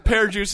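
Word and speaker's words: Per Djoos: Pear Juice is spelled P-E-R space D-J-O-O-S. – Pear Juice? – Oh, my God.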